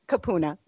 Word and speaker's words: kupuna: Kupuna, yeah.